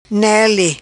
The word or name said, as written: Nele